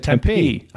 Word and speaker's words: Tempe: No, you said Tempe.